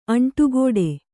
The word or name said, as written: ಅಂಟುಕೊಡು